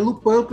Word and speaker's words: Lupin: É, se escreve Lupin, mas é Lupin porque é francês.